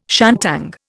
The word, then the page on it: shantung: Indian